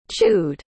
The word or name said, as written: chewed